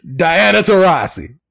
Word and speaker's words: Diana Taurasi: Diana Taurasi. – You said it right there, man.